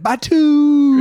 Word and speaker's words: Batuu: So when you do go to the planet Batu, I don't know, B-A-T-U-U. How would you say that, spaceman? – Batu. – Oh, that's how you'd say it.